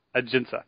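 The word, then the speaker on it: Ajinça: Ajinka.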